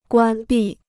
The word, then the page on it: 关闭: guān bì